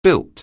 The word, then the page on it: built: anglais